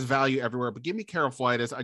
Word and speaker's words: Karlaftis: I think it's Karlaftis, but sure. Karlaftis sounds better. So let's just go with that.